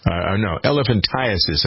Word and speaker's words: elephantiasis: These problems, the limbs, the interesting thing about this lymphatic problem is it's referred to often as elephantitis. Elephantiasis, I got to pronounce that correctly.